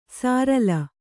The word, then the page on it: ಸಾರಲ: sārala